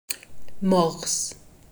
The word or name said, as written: Morse